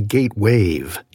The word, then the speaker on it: GateWave: We've learned that a lot of you have been hearing our name as Gateway, ending in W-A-Y. That may mean we haven't been pronouncing it clearly enough. It's really GateWave, G-A-T-E-W-A-V-E, like a sound wave or an ocean wave.